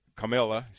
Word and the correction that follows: Kamala: Yes, In case you're wondering you. how to pronounce it. – Comma. – Comma. – Yeah. Like the comma.